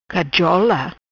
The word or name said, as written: Cajola